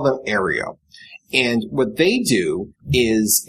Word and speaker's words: Aereo: I'm pronouncing it Aero. It's A-E-R-E-O. – Probably Aereo. – Aereo? – Well, there's an extra E in there.